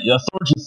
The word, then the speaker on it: Thorgy: You know, I agree with Allie that Thorgy Thor... and remember, it rhymes with orgy, so it's Thorgy.